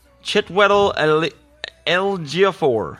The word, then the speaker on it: Chiwetel Ejiofor: Chiwetel Ejiofor, who plays Helmsley. Did I say that right? Mm-hmm.